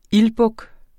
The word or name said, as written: ildbuk